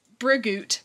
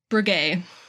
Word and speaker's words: Breguet: Wait, it looks like what? – Brigitte. Brigitte. B-R-E-G-U-E-T. Brigitte. – Oh, yeah. Yes, Brigitte, I think.